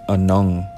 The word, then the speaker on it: Anung: In the Ojibwe language, star is pronounced Anung or Anungug if you're talking about more than one.